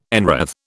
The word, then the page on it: enwreathe: British English